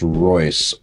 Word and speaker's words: Reuss: Okay, this gentleman, I think his last name is pronounced Reuss, R-E-U-S-S. So, Rolls.